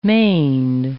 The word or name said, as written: maned